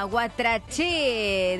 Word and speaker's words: Guatraché: Guatraché de La Pampa, acento en la E.